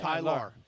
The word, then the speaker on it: Tylaur: I say that because his name is spelled T-Y-L-A-U-R, so okay. – I can't pronounce it. – Tyler.